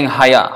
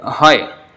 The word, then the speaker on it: hoy: So sometimes, especially we should take note of the last, like hoy, instead of saying haya. Haya is something totally out, that is not pronounced like that at all, haya. But the writing is like that, ha and ya, ha and ja. So if, hoy, that is actually written like that, but not pronounced like that, it's hoy.